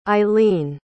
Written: eileen

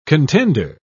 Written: contender

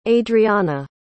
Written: adrianna

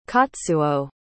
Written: katsuo

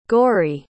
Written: goraidh

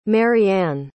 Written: maryanne

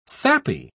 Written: sappy

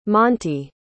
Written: montie

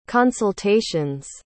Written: consultations

